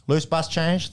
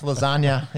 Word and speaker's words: Labuschagne: I was having a chat with the Saffa this week, boys, about the proper pronunciation of Manus Labuschagne. And Loose bus change. – That's pretty close, I thought. Manus Lasagna is another one that I've heard. It's not really anything like his name.